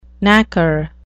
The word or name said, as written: knacker